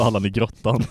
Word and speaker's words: grottan: Sen är det ett konstigt uttal här, men jag ska rätta honom. – Mm. Allan i gråtan. – Allan i gråtan. Alltså Allan i grottan.